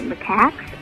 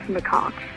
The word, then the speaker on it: macaques: I was catching up on some shows I missed, and on the January 4th show, you mentioned the cheap monkey whores, and you kept pronouncing it macax, but it's actually pronounced macaques.